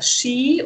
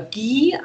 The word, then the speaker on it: Ghee: Ich verwende das äh, Shi oder Ghee ausgesprochen aus dem Ayurvedischen.